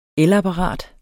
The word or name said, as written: elapparat